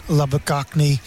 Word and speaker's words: Labuschagne: And obviously, there's talk with Labuschagne, Labuschagne, good pronunciation there.